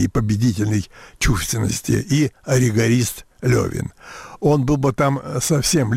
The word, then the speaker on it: Левин: Борис Михайлович, вы произнесли имя толстовского героя как Левин, но пишется во всех изданиях без точек над «е», без этих ежек, столь любезных Солженицыну. – Эхенбаум говорит, что писать и произносить следует Левин.